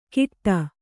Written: ಕಿಟ್ಟ

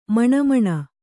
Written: ಮಿಣಮಿಣ

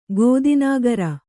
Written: ಗೋದಿನಾಗರ

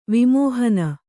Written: ವಿಮೋಹನ

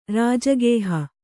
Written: ರಾಜಗೇಹ

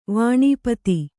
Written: ವಾಣೀಪತಿ

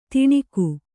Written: ತಣುಕು

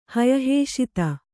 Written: ಹಯಹೇಷಿತ

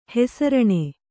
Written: ಹೆಸರಾನು

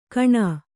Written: ಘೋಣೆ

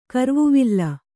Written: ಕರ್ವುವಿಲ್ಲ

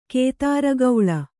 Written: ಕುದುರುಗೊಳ್

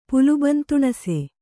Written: ಪುಲುಬಂತುಣಸೆ

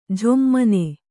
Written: ಝೊಮ್ಮನೆ

